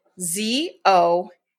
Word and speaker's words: zoanthropy: I feel like you jumping on the wrong syllable. Like the emphasis. Like you're putting the emphasis on the wrong syllables. – Yeah. It's like the ant, like the ant is more, you know, pronounced. So zoanthropy. But you're saying, you're saying zoanthropy.